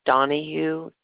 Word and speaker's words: Donohue: It's spelled C-Y-D-N-I. The last name is Donahue, D-O-N-O-H-U-E.